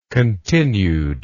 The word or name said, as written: continuedness